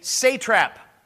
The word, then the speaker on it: satrap: He was made a satrap. S-A-T-R-A-P. I always say satrap, but that's not how it's pronounced.